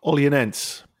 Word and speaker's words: Olhanense: Olhanense.